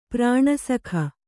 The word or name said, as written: ಪ್ರಾಣಸಖ